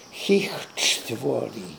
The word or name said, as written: Heechtvori